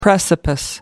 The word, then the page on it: precipice: American English